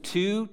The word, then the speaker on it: Tew: My last name is Tew, T-E-W is how it's spelled.